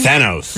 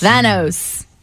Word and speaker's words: Thanos: Thanos. – Thanos.